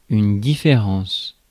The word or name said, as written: difference